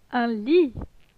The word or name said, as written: Un lit